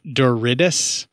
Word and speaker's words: Douridis: It's an actor named Chris Douridis, is I think is how it's pronounced, D-O-U-R-I-D-I-S, or Douridis, or what have you.